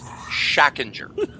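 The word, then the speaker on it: Schachinger: It is pronounced Shackinger. – I know. – Shaq, like the basketball player, Injure. Shackinger.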